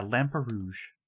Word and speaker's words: Lamperouge: So, we also learned that the Lamperouge? Is that how we pronounce it? – That's the last name, yeah. Lamperouge.